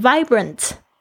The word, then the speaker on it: vibrant: 这个 V 其实是上排的牙齿轻轻的这个咬到这个下排嘴唇的一个 V 的发音。 Vibrant, I feel young and vibrant, 就是我感到年轻而充满活力。